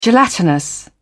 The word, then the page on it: gelatinous: British English